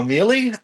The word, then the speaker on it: Miele: Connecting Dots, A Blind Life by Joshua Mealy. My name is spelled M-I-E-L-E, and it's available pretty much everywhere books are sold.